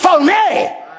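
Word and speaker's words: phoné (φωνή): Actually, in the Greek, it's pronounced phoné.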